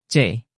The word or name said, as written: c